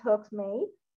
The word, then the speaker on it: Hogsmeade: Ves por primera vez Hogsmeade, perdón si lo pronuncio mal, no, sí, estuvo, perfecta pronunciación.